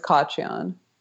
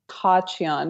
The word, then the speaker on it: Khachiyan: It's Khachiyan. – Is it Khachiyan? – Yeah. It's technically Khachiyan because it's an Armenian name. You don't say the K, but that sounds like you're like choking on a pubic hair, you know? – That's exactly what it sounds like.